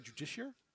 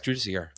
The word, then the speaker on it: Judiciar: Grimaldus, Primaris Chaplain, then 8 Assault Intercessors, 2x10 Crusaders, 3 Redemptors, a Judicar? Isn't it a Judiciar? – Judiciar. – Yeah. – Is it spelled Judicar, though? – Yes. – Is it? – It's Warhammer. We pronounce things in ways that make no sense. – Judiciar? Is that what okay. – It's Judiciar. All right.